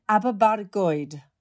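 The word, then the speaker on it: Aberbargoed: Am I getting my pronunciation right? You were born in Aberbargoid. – Oh, I love the rolling of the R, Gabby. That really is. That's spot on, my love.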